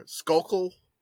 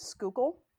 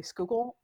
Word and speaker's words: Schuylkill: It's pretty close. So the like generally acceptable pronunciation is Skookle. – Oh. Oh, so close. Skookle. – Yeah, Schuylkill is acceptable. People who are from Schuylkill County say Skookle, or in the words of one of my dearly beloved friends from college, they'll just call it the Skook.